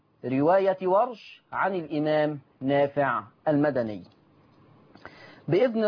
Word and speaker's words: ورش: بفتح الواو وسكون الراء، ورش.